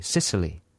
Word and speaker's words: Cicely: Is that how you pronounce Cicely? C-I-C-E-L-Y. Cicely. Cicely. – Yeah.